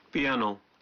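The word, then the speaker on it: پیانو، (ピアノ): پیانو، یہ پیانو کا جاپانی تلفظ ہے،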